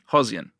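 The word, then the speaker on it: Khozyain: He's referred to during the book as, and I'm going to mispronounce this, it's Kozian? – Hozian. Yeah.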